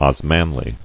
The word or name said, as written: Osmanlis